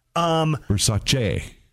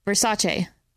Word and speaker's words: Versace: Versace. – Versace. – I don't know. I'm so confused. – The phonetic says C-H-E-H, so that's like Versace. – Che. – Versace. – It's like M-E-H is meh.